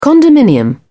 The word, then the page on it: condominium: British English